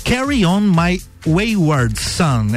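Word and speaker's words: Carry On Wayward Son: Teve Kansas, Carry On My I Word Song. Né? Eu, é difícil pronunciar o nome dessa música. Carry On My Wayward Sun, é isso o nome da música.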